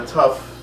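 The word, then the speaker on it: Tuf (תו): And the Aleph Bet, the last reverse of it is, what do you say, Tuf and Shin, right? Is that how you say it? – Are you talking about the last two letters? – Yes. – Yeah, are Tuf and then Shin. Yeah.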